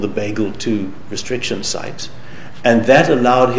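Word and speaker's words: BglII: It's pronounced Bagel 2.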